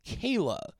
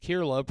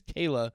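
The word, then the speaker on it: Kier-La: Horror and horror-related literature, finally released on audiobook as read by the author, House of Psychotic Women by, I found out that you pronounce her name Kayla. Her name is, it's spelled K-I-E-R-L-A. I always thought it was just Kirla, pronouncing it phonetically, but no, apparently it's Kayla.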